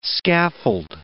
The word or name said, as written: scaffold